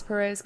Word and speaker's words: Perrault: Is he Ross Perrault's cousin? – No, it's spelled differently, like the French way. – It's like Perrault.